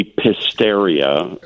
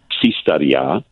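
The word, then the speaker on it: Psistaria: But yeah, it looks like it should be Pisteria. – Right. – That's how it's spelled. Pisteria. But the correct pronunciation is Psistadia.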